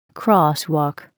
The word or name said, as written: crosswalk